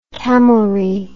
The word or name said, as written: camelry